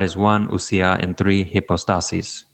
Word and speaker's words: hypostases: Ousia is singular, and hypostasis would be singular also, and then in the plural, it would be hypostases. So when you make the word plural, you have to move the accent over to the second to last syllable.